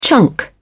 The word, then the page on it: chunk: British English